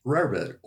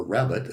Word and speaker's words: rarebit: Welsh rarebit, or rabbit as it's sometimes pronounced for the less cultured, is a food made up of what two items?